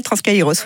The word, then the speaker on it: Transkairos: On prononce comme ça ? – L'Impérial Transkairos, oui.